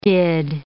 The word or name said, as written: did